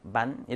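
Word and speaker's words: bánh: 지역에 따라서 발음이 조금씩 달라요. 북부에서는 바잉, 그리고 남부에서는 반 이렇게 하니까 지역에 따라 좀 달라.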